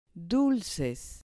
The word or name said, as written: dulces